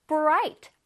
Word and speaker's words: bright: igh가 모여지면 i 소리가 납니다. 다시 해볼게요. bright. 우리 친구들 차례.